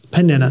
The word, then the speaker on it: Peninnah: He had another wife, and her name was Peninnah. Peninnah, that's how you say it.